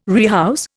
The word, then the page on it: rehouse: Indian